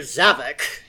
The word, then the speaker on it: Zavok: Well, it's a good thing you controlled all that extra blood pressure, Dr. Havoc. – Please, it's Dr. Zavok.